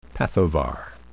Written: pathovar

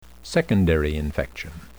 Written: secondary infection

